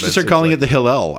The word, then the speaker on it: Hillel: Got my Hillel here. – The matzah sandwich is the Hillel. Yeah. I mean, and really nice pronunciation.